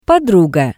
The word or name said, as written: Podruga